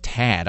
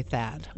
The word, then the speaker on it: Thad: But see, when you say Thad, I always assume that it's Tad. I always assume the T is silent. But then you mean the H? – The H is silent.